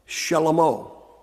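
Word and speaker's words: Shlomo (שלמה): Now, in Hebrew, his name is pronounced Shlomo.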